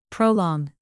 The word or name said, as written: prolong